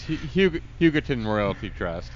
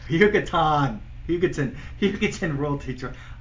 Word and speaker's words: Hugoton Royalty Trust: This is a stock actually that you turned me on to, and I'm going to butcher the name of it, but Hugotron Royalty Trust, that sounds about right. – It's a transformer. – Yeah, exactly. – It's Hugoton Royalty Trust. – Hugoton Royalty Trust.